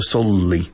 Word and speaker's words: solely: Soleli, escrito. Soleli, pronunciado solely. Solely. Sí. Pausa un poco en la L. Solely. Como si fuera una L doble, pero no lo es.